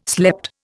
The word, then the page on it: slept: Indian